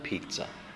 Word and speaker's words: pizza: Five, 下一个，The child is holding the cold pizza. The child is holding the cold pizza. 这边尽量你自己拼哈，自己拼。那有些字不太确定怎么念，可以听哈。特别讲 pizza， pizza，好，那是擦擦擦 pizza，好，不是比萨哈，中文翻叫比萨，英文、意大利文念叫 pizza。